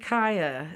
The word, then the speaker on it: Chaya: I will also highly recommend Kaya skates because it's spelled Chaya because they're really comfortable.